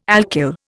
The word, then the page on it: alkyl: Indian